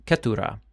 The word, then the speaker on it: Keturah: Her name was Keturah. It rhymes with the Hebrew word for crown, spelled differently, but it rhymes with crown.